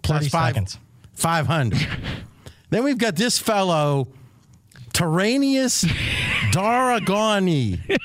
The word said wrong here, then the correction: Targaryen: Is that how you say it? – It's a new dinosaur, actually. It's Tyrion Lannister. – Yes. Yeah. No, it's not Lannister. – Oh, Daenerys Targaryen. – Yes, it's Targaryen.